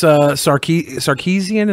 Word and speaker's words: Sarkeesian: You were creeping on Miss Sarkeesian. Is that how you pronounce it? – Yeah, that's how you say it.